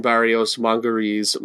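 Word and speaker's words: Barrios Mangoré: And you've just heard Lyra Carmer perform Augustine Barrios Mongeri's Mazurka Appassionata. And did I pronounce that correctly? – Yes, yes, you got perfect.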